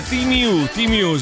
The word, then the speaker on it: Temu: Temu è Timiu, si pronuncia così.